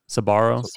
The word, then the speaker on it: Sbarro: Sbarro, Sbarro, the pizza Sbarro? Place. Sbarro.